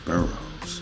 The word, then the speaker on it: Burroughs: Anna Julia Cooper and Nannie Helen Burroughs.